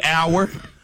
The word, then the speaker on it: hour: This was another one that threw me. Hour. – Hour. – Like time. – Yeah. The H is silent. – Exactly. H-O-U-R. The H is silent. Doubt. – But wait, I'm sorry. Mm-hmm. O-U-R is also hour.